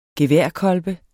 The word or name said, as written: geværkolbe